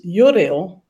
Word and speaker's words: Joreel: Yeah, it's Joreel.